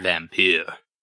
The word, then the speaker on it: Vampyr: Vampyr. – Vampyr. I think you pronounced vampire in the last episode.